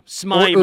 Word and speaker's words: Smyrna: Yeah, Smyrna.